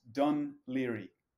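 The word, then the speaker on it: Dún Laoghaire: It's pronounced Dunleary. Dunleary.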